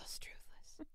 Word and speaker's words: Struthless: He's so Struthless. It just rhymes with toothless, so I keep...